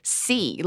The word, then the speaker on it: Xi: But in Chinese, XI is actually just C, like the ocean, or ABCD, like the letter C. So yeah, I think there's lots of sort of mispronunciations in English, like G or she. But it's actually, just think of the ocean.